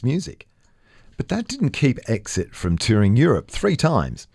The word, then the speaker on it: XIT: Even as that British quartet was laying the foundation, XIT, pronounced Exit, was singing about the Indigenous experience on its 1972 album, Plight of the Red Man.